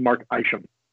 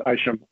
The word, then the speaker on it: Isham: And also, just by way of a slight correction, because he is a friend of the family and my wife's boss, it's pronounced Mark Isham. – Oh, okay. All right. – It's a common mistake. Even people who know him do it, but it's Isham.